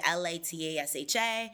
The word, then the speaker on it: Latasha: Twitter at Call Me Latasha is where you find me, LATASHA. If you want to find me on Spotify and all those places, it's Latasha with an accent on the A.